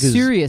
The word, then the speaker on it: Surya: Only how's Surya spelled? – Well, that's mainly because I'm not sure I'm pronouncing that properly. S-U-R-Y-A. – Surya. That sounds about right.